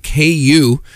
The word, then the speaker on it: Queue: Next up is a nice little tune from a band called KU, but it's spelled Q-U-E-U-E.